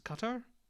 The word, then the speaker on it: Qatar: Qatar. I think if you're Arabic, you it's pronounced Qatar.